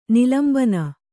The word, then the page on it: ನಿಲಂಬನ: nilambana